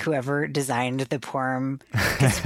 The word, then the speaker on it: Purim: Just Purim. – Okay. I think that's the Yiddish pronunciation. And then I think the Hebrew is like Purim, but I don't know.